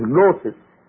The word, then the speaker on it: gnosis: You spell it with G, gnosis, but it is spelled, it is pronounced as gnosis.